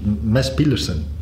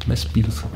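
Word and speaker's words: Mads Pedersen: Ja, maar daarnet in de wagen uh, vroeg ik aan jou, hoe spreek je Mats, Mats Pedersen uit? En dat was iets anders. – Ja, dat is Mes Piedersen. Mes Piedersen, Vermoed ik.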